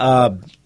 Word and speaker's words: Hogg: Is it David Hogg? – Hogg. Yes, anyway, Hogg. I've heard it pronounced Hogg, but it's H-O-G-G.